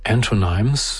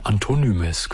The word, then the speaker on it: Antonymes: Das ist einer der aufregenderen Ambient-Künstler oder New Classical, wie auch immer Sie das nennen wollen, der dieses Jahrtausends, Antonyms, so würde ich ihn aussprechen, Antonymes können Sie auch sagen, The License to Interpret Dreams, das Album The Womb of the Great Mother, der Schoß der großen Mutter, am Muttertag, 10.